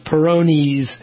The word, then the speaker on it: Peyronie's: E-Y-R-O-N-I-E, apostrophe S. It's pronounced Peyronie's disease.